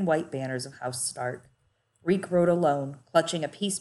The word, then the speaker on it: Reek: Reek. It rhymes with leak.